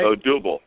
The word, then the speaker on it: Odubel: That's Odubel Herrera, if I'm saying it right. – Odubel. Yeah, it's Odubel. Yeah.